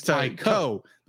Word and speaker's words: Taiko: Well, you're thinking of Taito, but this is Taiko. – That's right, yeah. Taiko. – I'm thinking it rhymes with Taito, which is a Japanese video game developer.